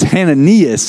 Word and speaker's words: Hananias: And his name, I've always called him Ananias, but in his name in the Hebrew, and if you study and pronounce it, I don't know, you guys may not care about this, but I care about it. It's Hananias.